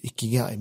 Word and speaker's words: ikigai: men det är ikigai heter det. Det är aldrig... Ikigai, eller vad sa du för något? – Ikigai, nej, ikigai.